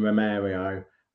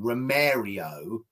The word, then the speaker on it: Romero: Are, are you going to be pronouncing him Romario the whole season? I I I, I pronounce it Romero.